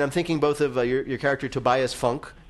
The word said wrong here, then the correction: Funke: am I saying that right? Funke, that is, in Arrested Development. – It is Funke, yeah.